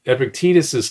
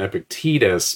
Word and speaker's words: Epictetus: Well, so in Epic, Epictetus's case, uh, I prefer the pronunciation Epictetus because that second E, the one in the middle, is an eta. So uh, it, it's a long vowel, Epictetus.